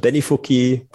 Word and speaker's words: Benifuki: Benifuku Powder Ichibancha. Habe ich es richtig ausgesprochen oder war es ganz falsch? – Benifuki Pulver Ichibancha.